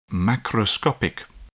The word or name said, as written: macroscopic